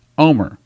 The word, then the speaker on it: Olmert: Now, you spell his name O-L-M-E-R-T. But I've been to Israel. I've heard it pronounced in Israel by several people there. And in Israel, it is pronounced Omer.